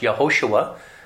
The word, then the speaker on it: Yehoshua: Now, in the Old Covenant or the First Covenant, sometimes the Vav that's after the Hey, it could be pronounced Yehoshua.